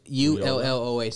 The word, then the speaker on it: Ulloa: It's Ulloa, so it's a double L. So yo. It's like Ulloa.